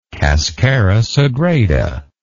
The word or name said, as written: cascara sagrada